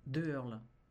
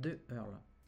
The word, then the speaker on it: Deheurles: De Hurl. – De Hurl ? – De Hurl. – Ah, ça s'écrit ? – En un seul mot, D-E-H-E-U-R-L-E-S.